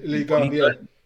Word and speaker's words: Ligue 1: How do you pronounce it? Ligue 1, Ligue 1.